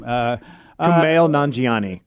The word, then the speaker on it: Kumail Nanjiani: We've had some great guest stars that have been on this, that have been in Twilight Zone, and and I'm looking at Kunal Najani. I can never say his name. – Kumail Nanjiani.